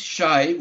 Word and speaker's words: Shay: Can I just a great club historian, Rhett Bartlett, and just follow this, tell the media, it rhymes, Shay, with up and away. – Very good. – Yes. – Okay. Not high in the sky, not high in the sky, Shay. Up and away, Shay.